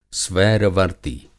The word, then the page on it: स्वैर-वर्ती: Hindi